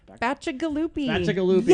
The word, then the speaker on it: Bacigalupi: It's Paolo Bacigalupi. – Bacigalupi.